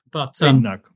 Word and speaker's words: Badenoch: Badenoch, right.